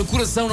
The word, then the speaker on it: coração: Meus queridos, vão cantar a palavra coração, não estiquem a primeira sílaba.